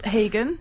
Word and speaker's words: Hagen: I go with Hagen, usually. – Oh, Hagen. – But it's not, neither is right, that's just what I allow. Neither's right. – That's what I allow English people to say, and I just go, sure.